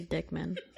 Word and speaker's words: Diekmann: D-I-E-K-M-A-N-N. – Okay. – How would you say that? – She was going to say Dieckmann. – Oh my God. – I knew it because I was going to think it too. – Am I wrong? – No, that's how I would pronounce it, yes.